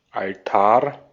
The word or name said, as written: altar